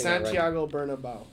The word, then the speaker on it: Santiago Bernabéu: They have three sets of sports, and the run they just recently came out with Real Madrid, Santiago Bernabéu Stadium. I'm not pronouncing Santiago Bernabéu.